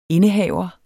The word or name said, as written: INDEHAVERS